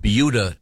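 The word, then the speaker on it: Buda: we have yet to get to sports, but we were discussing a very interesting news item from Beulah, Texas.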